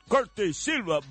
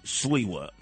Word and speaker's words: Sliwa: He said, Corte Silva, vaya! No. It's Sliwa. You want to pronounce it the right way, Polish way, Ukrainian way, Russian way? It's Sliwa.